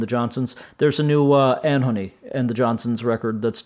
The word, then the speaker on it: Anohni: How do you pronounce it these days? Anhony. A-N-O-H-N-I. Anhony, I believe is how it's being said.